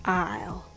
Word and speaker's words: I'll: How many syllables do you hear in I will and I'll? I will. I'll. You guessed it right. I will has two syllables and I'll has only one syllable.